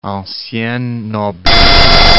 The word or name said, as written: ancienne noblesse